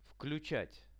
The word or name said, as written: vkluchat